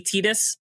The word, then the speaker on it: Tidus: Also, well, and to be fair, to be fair, I in Japanese is pronounced E, so it would be Tidus if you're reading the Japanese translation of it.